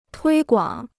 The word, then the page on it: 推广: tuī guǎng